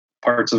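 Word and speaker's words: Pärt: He's the I think Estonian, it's Part.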